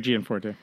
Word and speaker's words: Gianforte: Tonight is Greg was, so Greg is the, Gianforte, is that how you say it? Yeah, Greg Gianforte.